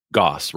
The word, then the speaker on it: Gosse: Gosse, rhymes with boss.